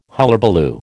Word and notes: hullabaloo: American English

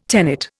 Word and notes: tenet: Indian

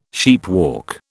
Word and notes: sheepwalk: British English

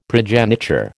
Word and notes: progeniture: American English

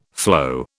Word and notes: flow: British English

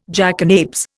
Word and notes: jackanapes: Indian

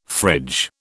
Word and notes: fridge: British English

